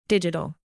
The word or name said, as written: digital